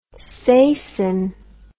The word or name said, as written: sasine